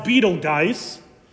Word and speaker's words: Betelgeuse: There are even there's a star known as, sometimes it's pronounced Betelgeuse, but I prefer the pronunciation Betelgeuse because I grew up in the 80s and I love that movie.